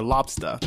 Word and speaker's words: lobster: In the Northeast, you can pronounce lobster as lobster or lobsta.